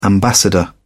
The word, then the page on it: ambassador: British English